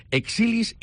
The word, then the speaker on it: Exilis: Y para eso tenéis dos máquinas en Pelvicali que son Exilis y Emsculpt. Explícanos, ¿qué máquinas son estas? Y sobre todo, ¿lo he pronunciado bien? – Perfecto.